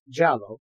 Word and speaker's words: Giallo: In Italian cinema, Giallo, pronounced Giallo,